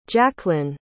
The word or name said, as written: jacklynn